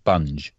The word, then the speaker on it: Bunge: I think that's how you say it. B-U-N-G. Bunge.